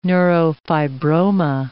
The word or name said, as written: neurofibroma